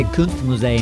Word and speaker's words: Kunstmuseum: Say Kunstmuseum.